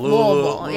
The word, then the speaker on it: Louisville: But I've been told tonight that you actually drove up from Louisville. – Yes, sir. – Am I saying it right? – Like, pretend you have just a whole bunch of cotton in your mouth. – Louisville.